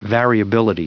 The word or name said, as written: variability